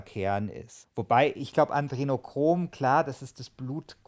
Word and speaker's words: Adrenochrom: Ja, ja, also das, äh, also das Interessante ist, dass dieses, äh, ich kann das nie aussprechen, Adenochrom, wie heißt das nochmal? – Adrenochrom.